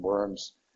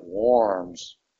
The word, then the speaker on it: Worms: That's not a correct pronunciation, Worms. It's more like Worms. It's a German town that is still in existence.